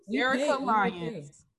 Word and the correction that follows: Derica Lyons: Say her name again. Her name is Derica Lyons.